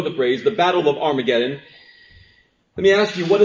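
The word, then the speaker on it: Har-Magedon: Our scriptures should say Har-Mageddon. This may be the first time that you've ever heard the word pronounced this way, Har-Mageddon. You may have even heard it and say, he's saying it wrong.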